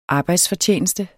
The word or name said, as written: arbejdsfortjeneste